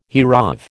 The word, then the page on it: hereof: American English